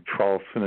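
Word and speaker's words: Traulsen: My name is Hugh, H-U-G-H, last name Charlson is spelled T like Tom, then R-A-U-L-S like Sam, E like Edward, N like Nancy, and then after my name, just put the words on manifesting your future.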